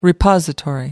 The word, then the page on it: repository: American English